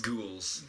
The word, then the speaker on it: ghoul: Nameless gals, quote unquote. – Ghouls. Gals. – You know, gals. – Ghoul. – I don't know how to pronounce that word, but now I do.